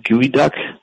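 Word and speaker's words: geoduck: Are you familiar with Gooey Duck? – Gooey Duck? I am not. – Yeah. Well, their mascot's called Speedy the Gooey Duck, and it's spelled like Speedy the, and it's G-E-O-D-U-C-K.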